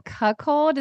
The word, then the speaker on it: cuckold: The other one I thought was so fascinating was the cuckold. Is that how you say it? – Uh-huh. – Yes.